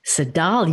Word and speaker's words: Tsedal: Seidel Neely, T-S-E-D-A-L. Am I pronouncing your name correctly? – Seidel, yes.